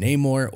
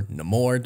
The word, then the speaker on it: Namor: Now, Marvel has been kind of setting up the mutants in the MCU with Kamala Khan and Namor, or Namor, depending on how you want to pronounce him.